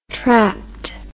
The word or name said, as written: trapt